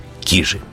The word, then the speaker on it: Кижи: И в этом случае ударение в конце. Но мы будем говорить «кижи».